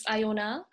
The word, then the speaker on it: Iona: Um, hi everyone, my name is Iona.